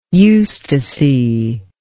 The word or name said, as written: eustasy